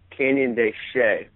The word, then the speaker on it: Canyon de Chelly: It's called Canyon de Chelly, which is spelled like Shelly, C-H-E-L-L-Y.